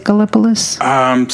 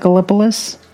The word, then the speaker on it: Gallipolis: Did I say that right? Is it Gallipolis? – Sounds great to me. – Gina, is that Gallipolis? All right, we're confirming that.